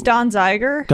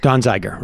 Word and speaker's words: Donziger: We got to figure out other ways of getting that Donziger thing out. I mean, I hope people tune into the whole show today. – It's Don Zeiger. – Don Zeiger, right.